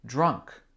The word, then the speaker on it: drunk: Pour le verbe boire, to drink, on a he has drunk. He has drunk. Drunk. Répétez. Drunk. Drunk. Ça s'écrit D-R-U-N-K. C'est avec le DR qui est dur parce que le son R est difficile en anglais.